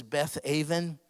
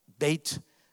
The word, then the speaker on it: Beth Aven: See, it says Beth Aven. It's pronounced Beit, house of Aven.